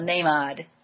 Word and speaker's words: Namad: So I will be here on January 18th to hear the online satsang with Daniel Namad, or Namad, I guess you're saying it with the long A, Daniel Namad.